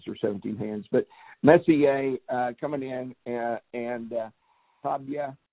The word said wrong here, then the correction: Taiba: I'm hoping that I pronounced that somewhere. – Taiba, that's right.